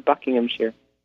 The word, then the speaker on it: Buckinghamshire: And the correct pronunciation is actually Buckinghamshire.